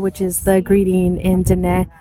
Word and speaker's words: Diné: So the language is, you say it's Diné. It's spelled like dine. – Right, with but it's pronounced high E at the end. – Diné. Diné.